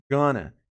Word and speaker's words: gonna: A dica de pronúncia é no going to. Going to, que vira o quê? Você já deve estar ficando craque nisso. Gonna, gonna, gonna.